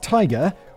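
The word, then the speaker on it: TIGHAR: In 1988, Richard Gillespie, founder of the International Group for Historic Aircraft Recovery, that's a mouthful, handily pronounced Tiger, which is a cool and better name, got on the case.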